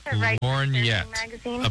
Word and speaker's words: lorgnette: No, lorgnette, a pair of eyeglasses or opera glasses with a handle.